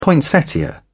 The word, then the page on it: poinsettia: British English